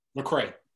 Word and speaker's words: Lecrae: Is it Lecrae or Lecrae? – Lecrae, yeah.